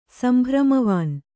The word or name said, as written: ಸಂಭ್ರಮವಾನ್